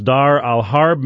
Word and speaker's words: Dar al-Harb: It's titled Dar al-Harb, Why You Need to Know This Arabic Phrase. I said it's pronounced Dar al-Harb.